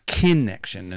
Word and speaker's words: Kinection: It's spelled K-I-N-E-C-T-I-O-N. So it's kinnection.